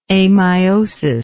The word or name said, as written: ameiotic